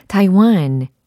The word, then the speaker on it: Taiwan: 대만 영어로는 Taiwan. 오, 대답하고 계신 분들 계시네요. Taiwan, Taiwan. 이렇게 발음하셔야 됩니다.